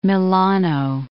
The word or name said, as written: Milano